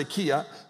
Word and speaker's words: Achaia: For the word of the Lord has sounded forth from you, not only in Macedonia, and it's actually Achaia, okay, in the way you pronounce it in that language, but, you know, we see Achai when we say Ai, but it's Achaia.